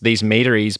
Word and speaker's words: meaderies: How did you get into making mead and how did these meaderies both grow and fell? That's the correct pronunciation of that, correct? Is that right? – Yeah, yes. I'll accept many, yeah.